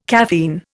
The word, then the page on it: caffeine: Indian